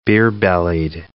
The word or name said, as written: beer-bellied